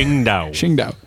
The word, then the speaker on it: Tsingtao: What are your thoughts on the Tsingtao beer lager? – Xingdao? – Yes. That's you know how to pronounce it? – Konnichiwa. Yes, I looked it up.